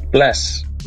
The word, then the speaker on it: bless: Bless. – Blass? – Bless. – Like with a B sound? – Yeah.